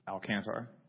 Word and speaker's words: Alcantar: Well, my name is Michael Alcantar, and I.